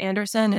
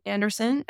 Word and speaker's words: Andersen: My name is Jessica Andersen, and Andersen is spelled S-E-N at the end. Mm-hmm. Also, my website is the same, Jessica L. Andersen, S-E-N at the end.